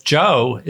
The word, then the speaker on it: Zhou: It's actually so simple because Zhou is just like an ordinary Joe, though he was anything but ordinary.